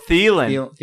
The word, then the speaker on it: Thielen: Oh, Thielen. – Thielen, yeah.